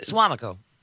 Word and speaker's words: Suamico: And I do actually, besides the volcanoes every once in a while, Hawaii is very different than it feels like the Suamico.